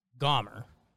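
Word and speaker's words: Gomber: According to Google, Austin Gomer is pronounced Gomer. It sounded a bit more like Austin Gomer, though.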